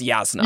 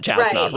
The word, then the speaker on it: Jasna: like we're used to Stormlight where it's like, oh, it's Yasna, yes. not Jasna, right?